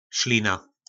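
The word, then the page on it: Slina: nářečí Po našimu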